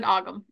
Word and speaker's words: Ogham: Because, like, I also love how I've been pronouncing Ohm wrong this entire, my entire 30 years of living. – People pronounce it Ogham as well.